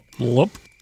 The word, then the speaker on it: Leupp: Jones' successor, Francis Ellington Lupp, L-E-U-P-P. How do you pronounce that? Lupp. – U-P-P.